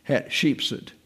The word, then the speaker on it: Hatshepsut: Hatshepsut. It's hard to say. You've got a T there you have to pronounce, and then an accent on the E, and then Suet. Hatshepsut.